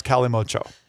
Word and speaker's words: Kalimotxo: Yo, but calimocho is spelled K-A-L-I-M-O-T-X-O, and it is pronounced... – The X is silent. – I know that it's pronounced calimocho, but then because I looked up the pronunciation, I did not look up the pronunciation of getcho, which is spelled G-E-T-X-O.